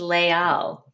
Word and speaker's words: Les Halles: You actually don't say the S because there's the H there. So it's just Leal.